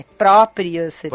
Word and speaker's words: propriocepção: Eu falei errado, minha mãe já me mandou um negócio dizendo que é propriocepção. Eu acho que eu falei propriocepção, né? É propriocepção.